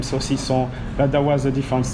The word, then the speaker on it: saucisson: Is it saucisson? – Yes. – Is that right. – How you pronounce it? – Saucisson.